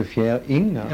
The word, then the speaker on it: Fjäringar: Säger du Fjäringar? – Ja, men det är väl Fjäringar. Det är riktigt.